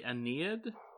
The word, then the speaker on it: Aeneid: I'm going to say the Aeneid is how I've heard it pronounced.